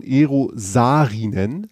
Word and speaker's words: Eero Saarinen: Und es ist letztlich eines der Hauptwerke von Eero Saarinen, sag ich jetzt mal. – Ja, das richtig. Ich glaube, das ist richtig ausgesprochen.